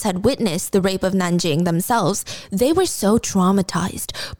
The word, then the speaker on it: Nanjing: Um, well, I guess the, the English term for that city is Nanking, but I believe the Chinese pronunciation is Nanjing. So I'm just going to go with Nanjing.